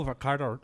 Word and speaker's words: Varadkar: It's Leo Varadkar. He's actually Taoiseach Varadkar.